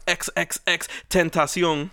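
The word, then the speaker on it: XXXTentacion: But after further review, they started seeing some of the people that had been blocked, and they blocked XXX. Tentacion.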